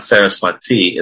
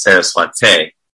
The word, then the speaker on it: Saraswate: Saraswati, by the way, it's not Saraswati, it's Saraswate. Some devotees don't pronounce it correctly, but it says Saraswate Deve, who's you, the servant of Saraswati Bhakti, his Guru Maharaj, Srila Prabhupada's Guru.